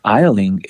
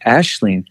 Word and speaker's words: Aisling: And I was like, "That's Eiling, Isling." He's like, "No, it's pronounced." And you're like, "It's pronounced Ashlyn." And he's like, "It's an Irish or Icelandic? What was it? Irish? – An Irish? It's a Gaelic word."